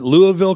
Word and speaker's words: Louisville: The largest towns in Kentucky are Louisville. There I got one. I knew I had it in there somewhere. – And you pronounced it right. Louisville, Kentucky, yeah.